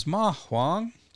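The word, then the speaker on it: mahuang (麻黄): All right, so that leads us to the gist, the center of what we're talking about today, which is mahuang. So those are both second tones, if you're familiar with Chinese.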